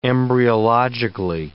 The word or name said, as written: embryologically